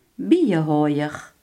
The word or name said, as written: imker